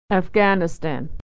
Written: afghanistan

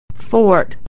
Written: fort

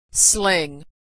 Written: sling

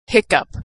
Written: hiccough

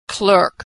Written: clerk

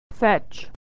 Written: fetch

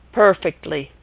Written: perfectly

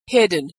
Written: hidden

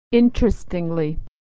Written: interestingly

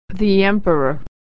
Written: emperor

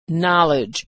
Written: knowledge

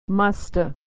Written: musta

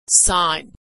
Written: signed

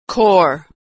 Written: corps

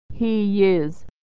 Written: he is

